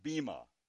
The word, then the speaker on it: bema: Now, of course, the word there for judgment seat is a word from the Greek that it's pronounced bema, bema seat.